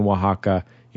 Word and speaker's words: Oaxaca: Maybe let's – I'm sure he doesn't mispronounce – It's definitely, I've never heard it pronounced anything other than Oaxaca, including when I was there with my Spanish-speaking mother.